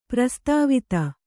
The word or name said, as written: ಪ್ರಸ್ತಾವಿತ